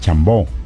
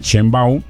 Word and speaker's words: Sheinbaum: La presidenta Sheinbaum, ¿cómo se escribe? Así es como debe pronunciarse. – Sí, señor. – Algunos dicen Chambón.